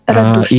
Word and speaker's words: Радушне: Журналістка Першого Криворізького, розповідала нам про трагедію у селищі, воно називається Радушне. Дякую. – Радушне. – І друзі, Радушне, так, дякую за те, що ви виправили наголос, так, бо я у вас не живу, але разом з тим мені завжди приємно, коли колеги підтримують в цьому плані.